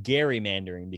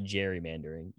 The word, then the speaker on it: gerrymandering: This is like when John Mulaney was on Seth Meyers and told this story about how it's pronounced gerrymandering because the guy's name is Gary, not gerrymandering, even though it's widely accepted as gerrymandering.